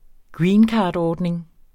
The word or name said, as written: greencardordning